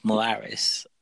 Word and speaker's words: Morales: So in the comics, Miles Morales, uh, I can't even say his name.